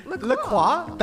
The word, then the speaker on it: La Croix: La Croix? That's how you're supposed to say it? – Real pronunciation. – It's a French word, right? – Wow, I love that.